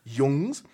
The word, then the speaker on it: Jungs: And also on 14th, this lovely day, will be given, we will be given Personality Cult's album New Arrows, Herzblut with Berliner Jungs. I believe that's how it's pronounced. – You're right. – Jungs.